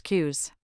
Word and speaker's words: QWS: It's a total mindset shift, said Kenta Iwata, 28, a community manager at the cross disciplinary project Shibuya QWS, pronounced Qs.